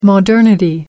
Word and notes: modernity: American English

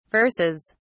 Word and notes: verses: British English

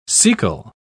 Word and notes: cecal: British English

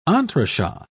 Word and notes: entrechat: British English

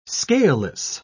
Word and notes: scaleless: British English

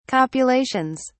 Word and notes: copulations: British English

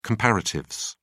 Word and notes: comparatives: British English

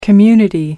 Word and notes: community: American English

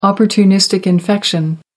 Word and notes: opportunistic infection: American English

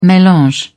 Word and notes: melange: British English

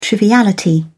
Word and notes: triviality: British English

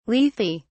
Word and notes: lethe: British English

